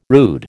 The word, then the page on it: rude: American English